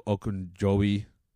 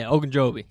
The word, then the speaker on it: Ogunjobi: Larry Ogunjobi. Said it right. – Yeah, Ogunjobi.